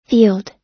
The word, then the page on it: field: American English